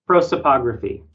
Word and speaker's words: prosopography: So that article would be Race in Computing, the Problem of Sources, the Potential of I'm sorry, how do you say that word? – Prosopography.